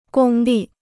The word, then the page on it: 公立: gōng lì